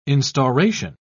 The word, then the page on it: instauration: British English